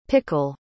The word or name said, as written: pickel